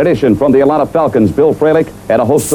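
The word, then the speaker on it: Fralic: In addition, from the Atlanta Falcons, Bill Frelick, what? And a host of others. – You hear that? He said it right.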